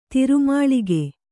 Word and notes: ತಿರುಮಾಳಿಗೆ: tiru māḷige

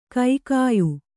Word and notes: ಕಗ್ಗಾಯಿ: kannada

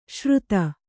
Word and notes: ಶ್ರುತ: Kannada

